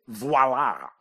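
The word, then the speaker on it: voilà: No, this word is voila, voila. And remember, even though spelling is not important, voila is not spelled like viola. It's V-O-I-L-A, but you didn't have to spell it right.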